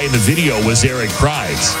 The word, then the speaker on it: Prydz: Is it Eric Prydz? – Yeah, but it's Prydz. – P-R-Y-D-S.